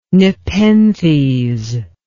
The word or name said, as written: nepenthes